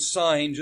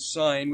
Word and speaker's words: Sin: I'm pronouncing it Sin just to make it's S-I-N, it's not the word sin, but it's the name of the place,